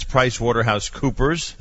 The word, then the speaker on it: PricewaterhouseCoopers: All right, Brian Ness is with us, and it's PricewaterhouseCoopers.